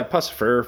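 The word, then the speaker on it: Puscifer: Is that how you say it? – Oh, yeah, Pussifer, fucking Maynard James Keenan shit.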